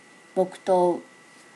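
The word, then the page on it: bokuto: native Japanese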